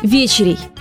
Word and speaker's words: вечеря: Вы наверняка знаете, что в старину поздний прием пищи, вечернюю трапезу, ну или, проще говоря, ужин, называли вечерей. Да, ударение строго на первый слог.